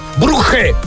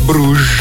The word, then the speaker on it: Bruges: Stamattina stavo ascoltando il notiziario alle 8 all'interno del Dermic Show e il giornalista del TGcom ha detto Club Bruges, come se fosse in tedesco. In realtà è una squadra belga, quindi è Bruges.